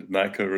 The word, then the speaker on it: Naicker: Naicker, is that how I pronounce it? – It's Viroshan, and Naicker is right.